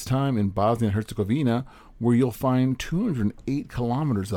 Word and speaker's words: Herzegovina: Bosnia and Herzegovina, I said that right.